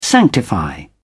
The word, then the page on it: sanctify: British English